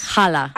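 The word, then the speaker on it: challah: Jala. – Ah, jala. – Jala, sí. – Jala. – En inglés se escribe con CH, pero en español es con J, entonces jala.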